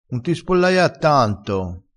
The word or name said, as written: spollaiassi